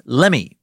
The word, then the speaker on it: Lemhi: They were the first group of Americans across the Continental Divide via the Lemhi Pass, often pronounced Lemmy by fellow Idaho mushmouths like myself.